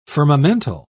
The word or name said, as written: firmamental